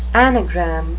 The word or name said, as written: anagram